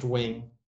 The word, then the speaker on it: Nguyen: Oh my god, yeah, there's this guy named Wing, spelled N-G-Y-N-G or something, or N-G-U-Y-E-N, pronounced Wing.